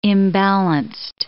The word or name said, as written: imbalanced